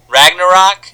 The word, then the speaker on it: Ragnarok: Ragnarok? – Yeah, you said it right.